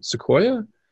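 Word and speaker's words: Sequoyah: pronunciation, Sequoia, I think, is it? – That's correct.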